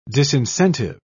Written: disincentive

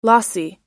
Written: lossy

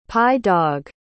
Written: pye-dog

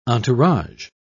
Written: entourage

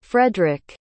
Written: fredric

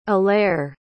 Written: alair

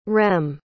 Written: rem

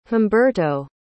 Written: humberto

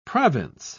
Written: province

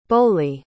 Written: boley

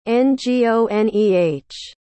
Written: Ngoneh

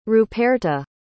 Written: ruperta

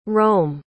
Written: reaume

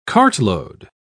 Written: cartload

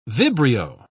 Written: vibrio

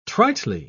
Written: tritely

